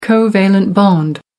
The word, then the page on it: covalent bond: American English